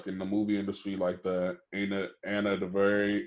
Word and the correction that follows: DuVernay: Ava DuVernay.